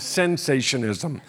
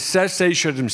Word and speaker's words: cessationism: I'm going to spell it for you just so you don't think I'm saying sensationism. It's cessationism, C-E-S-S-A-T-I-O-N-I-S-M, cessationism.